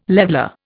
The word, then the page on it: leveller: Indian